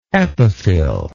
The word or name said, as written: epiphyll